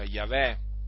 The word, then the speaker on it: Yahweh: Il tetragramma che appunto si pronuncia Yahweh e infatti lì il termine, eh, il nome, il nome di Dio in ebraico è Yahweh.